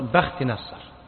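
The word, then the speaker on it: بختنصر: على كل حال بخت نصر أو بخت نصر هي كلمة فارسية، الصواب أن النطق بخت نصر، بخت معناه الحظ، والنصر بمعنى الظفر، أي الحظ الظافر معناها بالفارسية، كلمة فارسية.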